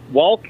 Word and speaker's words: walk: He's pronounced walk, and he said walk and talk. Pronounced the L in walk and talk.